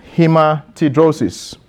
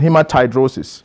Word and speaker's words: hematidrosis: There is a condition known as hematidrosis, right? Hematidrosis. Some people pronounce it hematidrosis.